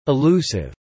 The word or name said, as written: elusive